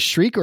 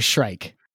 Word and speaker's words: shrike: You want to call it a shriek or a shrike? – It's a shrike because it's that's the name. That's the way it's spelled.